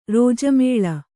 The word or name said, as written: ರಾಜಾಮ್ಲ